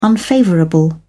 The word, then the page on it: unfavourable: British English